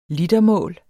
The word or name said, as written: litermål